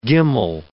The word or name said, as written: gimmal